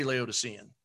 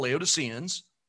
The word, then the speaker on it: Laodicea: So the person who fits in this category of this last and seventh church era, which we call Laodiceans, it's probably pronounced Laodicea, but we've Americanized it.